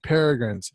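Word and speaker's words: Peregrine's: Another film to add to that was the Miss Peregrines, I think is what it's pronounced, but the Peculiar Peregrines. Children. Yes, Peregrines. That's right, Peregrines.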